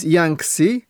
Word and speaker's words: Jancsi: My first name is Yangtze. That's spelled J-A-N-C-S-I. – Sorry, was that J-A-N-N-C-S-I? – No, just one N, J-A-N-C-S-I.